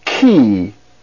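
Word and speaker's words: Ki (χ): That's Ki. Its phonetic sound or pronunciation is the CH sound, as in the word chemistry.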